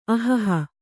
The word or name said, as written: ಅಹಹ